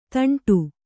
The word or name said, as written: ತಾಂಟು